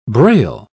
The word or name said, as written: braille